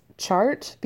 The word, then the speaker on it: Tjaart: 21 days to wed are left, and Sinjin is out with his South African friend that I'm going to pronounce Chart because that is as close as I can get, but it's spelled T-J-A-A-R-T.